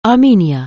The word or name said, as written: Armenia